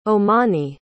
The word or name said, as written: Omani